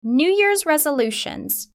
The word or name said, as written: New Year's resolutions